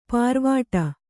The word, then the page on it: ಪಾರ್ವಾಟ: pārvāṭa